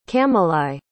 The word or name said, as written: camelai